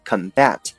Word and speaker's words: combat: OK，接下来一个单词叫做 combat，这个单词啊有两个读音啊，如果呢你读成 combat，它就是动词啊，叫做和谁谁搏斗或者是战斗的意思，拼成 combat，combat。那还有一个读法呢，叫做 combat，此时呢重音在前面，我们知道重音在前面一般都是名词性的，所以 combat 作为名词表示的是格斗或者搏斗、战斗的意思。